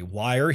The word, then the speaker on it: Wyre: Wire here is spelled W-Y. R-E.